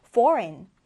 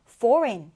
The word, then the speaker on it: foreign: 고 묵음 처리가 되어서 foreign, foreign 이렇게 발음해 주는데요.